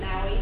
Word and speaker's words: Maue: Mary Maui, M-A-U-E.